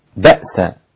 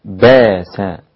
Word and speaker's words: بأس: في قوله تبارك وتعالى أن يكف بأس الذين كفروا، أبدل الإمام السوسي والإمام أبو جعفر فقط بأس، فيصير النطق بها باس، والإبدال بسيط، حكم الإبدال بسيط، بشيل الهمزة وحط مكانها ألف، بدل ما أقول بأس باس هكذا.